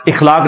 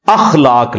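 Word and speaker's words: اَخلاق: مثلا یہ لوگ بول دیتے ہیں اخلاق، اخلاق لفظ نہیں ہے، اخلاق لفظ ہے. تو اگر ہم یہ لفظ یوز کریں گے تو الف کے اوپر زبر ڈالیں گے.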